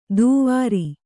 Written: ತುವರಿ

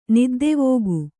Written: ನಿದ್ದೆವೋಗು

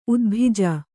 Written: ಉದ್ಭಿಜ

